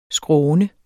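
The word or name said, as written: SKRÅNET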